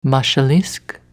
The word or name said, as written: Mashalisk